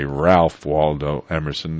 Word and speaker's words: Ralph Waldo Emerson: I should say Ralph Waldo Emerson. That's pronouncing it a little more New England style, and he's from Concord in Massachusetts.